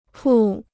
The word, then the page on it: 铺: pù